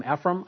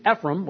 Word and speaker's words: Ephraim: Ephraim. I might prefer the pronunciation Ephraim, which is just kind of an American quick way of saying it. But if somebody wanted to call me Ephraim, it's all going to sound a little bit different.